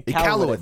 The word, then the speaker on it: Iqaluit: Like I'm just seeing tweeted out right now that the mayor of Iqaluit, I think I said that correctly. If I didn't, excuse Iqaluit? Me. Iqaluit, yeah. Yes, yeah. Has asked for all flags. – Or Iqaluit. – Iqaluit, there we go.